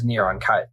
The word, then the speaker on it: Neron: Well, yeah, how they say it in Hebrew is Neron Caesar.